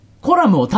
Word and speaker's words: コラム: 一応、コラムを単体で言うときはコラムって言ってあげてね。コラム企画、コラム記事とかっていうときは、後ろにつくから、イントネーションはコラム、うんふんになるんだけど。うん、コラム。